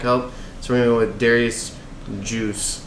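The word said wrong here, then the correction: Guice: It's guys. – You're close. – I knew it wasn't pronounced. – G-U-I-S-E? – Yeah, it is. – Yes. – I knew it was not Juice.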